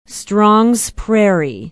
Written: strongs prairie

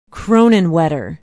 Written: kronenwetter

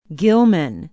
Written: gilman

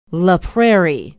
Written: la prairie

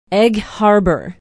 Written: egg harbor

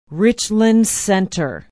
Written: richland center

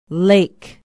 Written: lake

